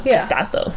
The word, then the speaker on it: DiStasso: DiStasso, like Picasso, but DiStasso.